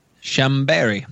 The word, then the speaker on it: Chambéry: All right, so Blanc, or white, vermouth is a late 19th century innovation from Chambéry. I don't know how to say it. Okay. – Chambéry.